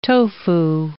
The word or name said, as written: tofu